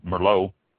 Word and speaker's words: Merlot: I hate to say it, but Merlot is really mispronounced more often than we care to admit. A lot of people put the T on it, and in France, if you have a word ending in a consonant, it's usually not pronounced, usually silent, so it would be a Merlot, although it does end in a T. So, to start with here, Chardonnay, spelled C-H-A-R-D-O-N-N-A-Y, pronounced Chardonnay.